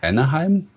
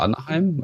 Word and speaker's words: Anaheim: Also die Build 2011, die war im September 2011 in Anaheim. Spricht man das so aus? Mhm. Oder Anaheim? Egal.